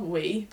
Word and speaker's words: Huy: No, so we stayed in, I think it was called Wee, but it's spelled H-U-Y, like Wee.